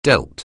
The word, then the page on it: deal: anglais